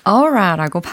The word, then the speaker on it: aura: 이거는 aura라고 발음이 되었는데 철자는 a u r a라는 겁니다. – Yeah. – 그래서 우리가 쉽게 아우라라고 이야기를 하죠. – 아우라. 어. It's like a feeling. – Yeah. 그래서 어떤 기운이랄까 아니면 분위기 혹은 아우라라는 단어의 정확한 발음이 바로 aura aura 이렇게 발음한다는 거죠.